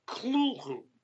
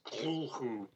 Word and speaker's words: Cthulhu: But Lovecraft himself had the sense that it should be pronounced almost like you were trying to cough up a hairball, sort of Cthulhu. And there are people in the Lovecraft community who continue to pronounce that name as Cthulhu to this day. But it's a little, to my mind, maybe a little affected.